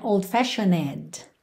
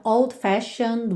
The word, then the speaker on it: old-fashioned: Então, aqui, muita atenção a essa palavra old-fashioned, porque tem um E aí, mas você não pronuncia, você não vai dizer old-fashioned, ok? Então, old-fashioned way, old-fashioned way.